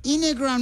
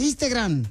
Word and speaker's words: Instagram: Ya sé que es un show, pero pronuncia bien Facebook. – Renuncia. – No es Facebook. Y también Instagram, no es Instagram, es Instagram.